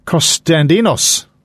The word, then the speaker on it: Costandinos: Love and Kisses were a disco group assembled by European producer Alec Costandinos. That's probably not how you say his name. Costandinos. That sounds more like it.